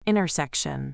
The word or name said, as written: intersection